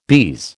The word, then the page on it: bise: American English